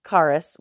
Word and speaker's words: Charis: So first thing I want to talk about is charis, which is grace. It's a Greek word for grace. It is spelled Charis. It looks like Charisse or Charisse.